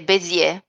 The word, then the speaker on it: Bézier: Credo sia francese, quindi è Bézier.